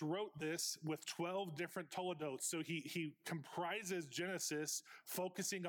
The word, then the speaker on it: toledots: there's a list of toledotes. Toledotes is spelled T-O-L-E-D-O-T-S.